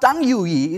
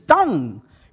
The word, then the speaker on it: tongue: Any word which ends in UE, it has to be pronounced so silently, it's not tongue UE, it is tongue.